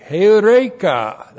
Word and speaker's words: heureka: H-E-U-R, long E-K. Hey, heureka, that's the perfect tense, which means, I have found it.